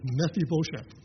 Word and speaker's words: Mephibosheth: In case you're curious, the right pronunciation is Mephibosheth.